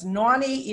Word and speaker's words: Nonnie: So it's Naughty, even though it's spelled N-O-N-N-I-E, Naughty Boss. I pronounce it with an A-U. But I spell it with an O-N.